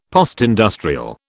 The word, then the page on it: postindustrial: British English